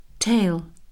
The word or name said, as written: tail